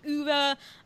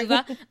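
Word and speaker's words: Ove: and he wrote A Man Called Uva, as well as Yes, I'm so glad you pronounced that correctly. – A Man Called Sorry. Uva.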